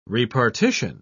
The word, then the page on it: repartition: British English